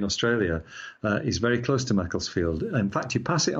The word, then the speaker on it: Macclesfield: It's in the north of England, so it's pronounced Macclesfield with a very short flat A, I, Macclesfield.